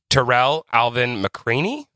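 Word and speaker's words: Tarell Alvin McCraney: I'm going to say this wrong, and please correct me. Terrell Alvin McCraney? – That's right.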